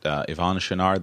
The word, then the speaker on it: Yvon Chouinard: Is it Yvon Chouinard, the guy who founded Patagonia? Is that how you say his name? – Yeah.